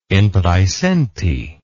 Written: in praesenti

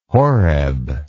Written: Horeb

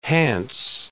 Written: Hants

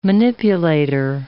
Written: manipulator

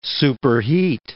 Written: superheat

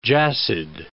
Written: jassid

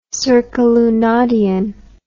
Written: circalunadianly

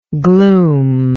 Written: gloomfully

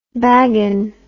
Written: bagan

